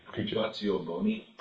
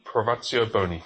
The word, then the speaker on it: privatio boni: Privatio boni. – Privatio boni.